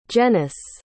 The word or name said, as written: Jenece